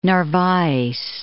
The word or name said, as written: Narvaez